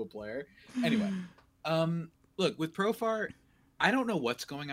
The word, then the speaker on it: Profar: Profare, man. Profare. That's how I always say his name. I don't know why I add that to it. He's from Curacao, man. He's not from Italy. – I don't know why I do that. I still don't know why I do that. I know how to pronounce his name.